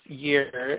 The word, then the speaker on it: Yr: The third rune that came up is another of the Northumbrian set, and it is pronounced Yr, and it's spelled Yr.